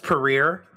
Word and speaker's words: Perrier: I'm drinking Perrier with a little lemon. Yes. – Oh, yes. I believe it's pronounced Perrier.